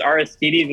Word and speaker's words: Aristides: Aristides. Is that your, no, it's not bad. – Okay. – Yeah. I'm pretty sure it's Aristides. It's like the Greek god.